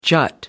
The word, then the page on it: jut: American English